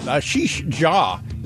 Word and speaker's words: Ashish Jha: Back in a second with Ashish Jha, is how you say his name.